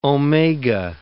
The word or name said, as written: omega